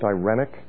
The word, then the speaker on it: irenic: You could write it down, E-I-R-E-N-E. It's the root of our word irenic, sometimes pronounced irenic.